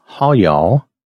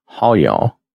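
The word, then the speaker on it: hayah: And the I am is a word that is pronounced, now I'm going to do the best I can with this, Hawyal. That may not be exactly correct, but that's how it's spelled out, Haw-Yaw.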